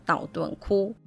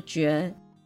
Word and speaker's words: 道頓堀: 说到大阪，相信十个人有九个半都会想到道顿窟。我没有念错，真的是念窟而不是绝。大家会念成绝的原因是“ 窟” 这个字和“ 绝” 这个字长得真的很像，但其实是两个完全不同的字，一个是手字边，一个是土字边。